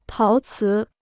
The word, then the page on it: 陶瓷: táo cí